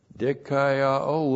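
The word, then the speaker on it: dikaioō: Dikaiao looks like this, D-I-K-A-I-O, long O, dikaiao.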